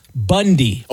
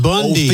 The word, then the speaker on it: Bounde: The problem is, though, their last name is B-O-U-N-D-E. And that's pronounced Bundy. – Pronounced Bundy.